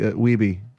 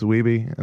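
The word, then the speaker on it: Wiebe: There's even a scene where, like, you know, fucking Walter Days in King of Kong is going, Steve Weeb, everybody. He's like, Weeby. It's Weeby. And then, like, they're like, oh, we've been saying it wrong the whole time. – You just have to remember the cover of Lean on Me. We be jammin'.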